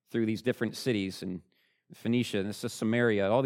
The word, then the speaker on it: Phoenicia: That's how you pronounce it, Phoenicia.